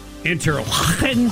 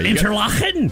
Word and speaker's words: Interlachen: And isn't it pronounced Interlachen? Yeah, Interlachen. – yeah, if you're not uh, getting some saliva on somebody's face, you're not saying it right.